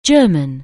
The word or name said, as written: German